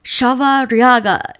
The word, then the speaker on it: Chavarriaga: I am super excited to talk to Vanessa Chavarriaga.